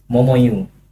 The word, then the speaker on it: Momoyo: Pertama cara bacanya sebenarnya bukan Momoyo dan. Itu? Momoyu. Cuman Momoyu. betul. Jadi kebanyakan orang sebenarnya salah sebut sih itu. Bukan Momoyo tapi Momoyu. Tapi yang terkenal Momoyo.